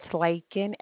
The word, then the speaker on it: Laken: It's Lakin, L-A-K-E-N.